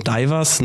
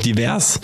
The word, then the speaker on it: divers: dass es sich nicht um den Lieferanten Divers nach englischer Aussprache handelte, sondern es sich lediglich um Divers handelte.